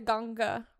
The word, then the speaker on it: Ganga: They call it the Ganga River.